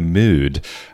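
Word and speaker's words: Møde: Well, I think the correct pronunciation would be In the Mood, because the O in Mode has a sort of Norwegian or Danish slash through it. But In the Mode, second studio album by Roni Size and Represent.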